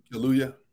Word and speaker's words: Kaluuya: Daniel Kaluuya? Is that how you say it? – Kaluuya. – Kaluuya? Yeah. Okay.